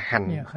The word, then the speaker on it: hạnh: Chữ hạnh này đọc khứ thanh, đọc là hạnh.